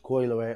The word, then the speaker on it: Coi Leray: Coi Leray, mm-hmm.